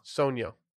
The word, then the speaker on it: Sogno: Sogno. – You don't pronounce the G.